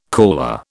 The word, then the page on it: caller: British English